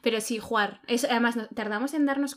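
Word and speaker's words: Hvar: Y se pronuncia Juar.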